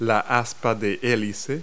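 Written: rotor blade

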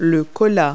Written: cola

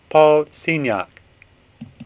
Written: Signac

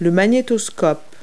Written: VCR

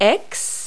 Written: x

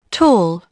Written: tall

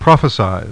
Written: PROP

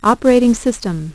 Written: operating system

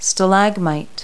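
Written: stalagmite

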